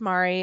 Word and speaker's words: Mari: It's pronounced Mary. It's spelled Mari.